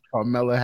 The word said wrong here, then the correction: Kamala: Harris. – No. – That's not her name. – I can never pronounce her name. – Kamala.